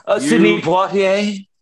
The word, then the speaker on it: Sidney Poitier: Sidney Poitier.